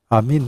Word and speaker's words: amin: Amin nu este altceva decât preluarea și ajustarea vechiului amen în greaca modernă, amin, că eta era e, dar acum este i în greaca modernă.